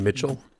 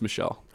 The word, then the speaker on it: Michel: Uh, Sony and, Mitchell, huh? Is it not Sony Mitchell? It's Michelle. I've been pronouncing that wrong.